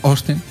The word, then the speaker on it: Austin: Vale, permíteme que lo pronuncie bien. Austin. – Con O, ¿no? La A, uy, eso. – Bueno, la A no la dicen. Me fijé, Austin.